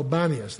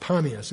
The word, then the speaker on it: Panias: The idols up in the north, the golden calf, and if you go up there to what they call Banias. That's because the Arabs mispronounced Panias. It was a Greek region dedicated to the god Pan and called Panias, and the Arabs call it Banias, and so now you'll find on road signs B-A-N-A-I-S.